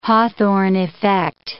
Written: Hawthorne effect

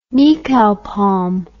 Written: nikau palm

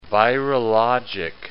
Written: virologic

